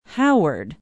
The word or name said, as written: howard